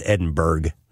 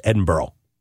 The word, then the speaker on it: Edinburgh: Edinburgh, and you do pronounce it Burrow, even though it's B-U-R-G-H. I learned that. I thought it was Edinburgh. No, it's Edinburgh. That's how you pronounce it.